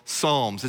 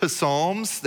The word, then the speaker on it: Psalms: I want to go to the very middle of God's word, to a book that we call Psalms. It's a book of songs. And some people pronounce it psalms. They think that it's psalms, but the P is silent, as it always should be in our lives.